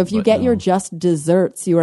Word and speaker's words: deserts: It is DESERTS, spelled like deserts, but pronounced desserts like a deserter because it's the state of deserving something.